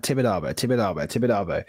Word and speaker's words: Tibidabo: and I switched the camera on and I went Tibido. – Red light syndrome. – Oh mate, it's so bad. Like I was listening to pronunciation. I was like, yeah, right, Tibidabo.